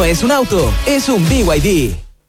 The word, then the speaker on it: BYD: ¿Los VID? – ¿VID? ¿No es VID? – Nada que ver. Se escribe BYD, pero se dice BYD.